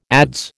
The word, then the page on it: adze: American English